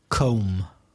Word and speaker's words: comb: Comb. – C-O-M-B. – Comb. That word often gets mispronounced, doesn't it? – Do you use a comb?